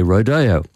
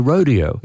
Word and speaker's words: rodeo: But I am told in the Midwest of America, they say rodeo. And on the East Coast, they say rodeo. So if you want to know what the American pronunciation is, the correct answer to that is depends where you are.